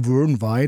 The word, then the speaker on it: Vyrnwy: Und ich war zum Beispiel, das war aber nicht ganz günstig, am, jetzt wieder bitte Aussprache, Lake Vyrnwy, also geschrieben wird es Vyrnwy, aber ich glaube, man spricht so Lake Vyrnwy.